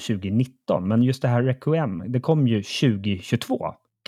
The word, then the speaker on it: Requiem: Och ja, apropå uttal, nu tungan rätt i mun här, men eh, A Plague Tale Requiem Requiem, ja. Requiem. ja nästan, nästan. – 99 procent rätt, yes.